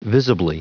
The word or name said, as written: visibly